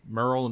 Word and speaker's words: Merle: So, Merle Dandridge, or Merle, is it Merle? How do you say Merle. – His name, director? – Merle.